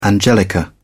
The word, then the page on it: angelica: British English